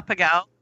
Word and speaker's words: Pegau: Close enough. Yeah, that's okay. Pegau, yeah. Pegau. It's actually, yeah, it's German.